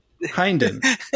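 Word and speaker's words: Hynden: Hinden? Hinden. Like Hayden, but Hinden.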